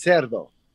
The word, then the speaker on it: Cerdo: or is it Curdo? How do you pronounce that one? – It's Cerdo. – Cerdo. – Cerdo, which means pig in Spanish.